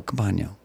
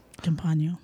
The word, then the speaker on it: Campagno: Campagne. No, Campagno. – Campagno.